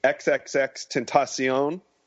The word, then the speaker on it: XXXTentacion: We saw earlier this week XXXTentacion. That's how you say it.